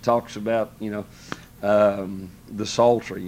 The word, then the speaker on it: psaltery: he doesn't pronounce it right, calls it the pizzle tree.